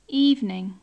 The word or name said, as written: evening